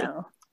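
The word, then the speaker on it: duxelles: A U in French has a very ooh. – Ooh, d'uxelles, so not d'uxelles. Okay, exactly. D'uxelles.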